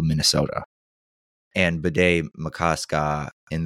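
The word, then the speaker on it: Bde Maka Ska: Oh, and if you're wondering, the lake Bede Makaska, the correct pronunciation is Bede Makaska. Pardon me. It's spelled BDE space MAKA space SKA.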